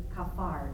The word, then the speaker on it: kaphar: I don't have a slide for this, but just as a side note, the word for atonement in Hebrew is kafar. It's spelled K-A-P-H-A-R, and it literally translates as to cover.